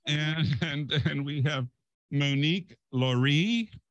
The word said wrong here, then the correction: Leray: Well, you almost got it right. Monique Leray. – Leray.